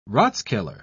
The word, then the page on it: rathskeller: British English